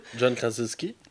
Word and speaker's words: John Krasinski: John Krasinski. voilà, merci de le prononcer pour moi, parce que tu as ri de ma gueule.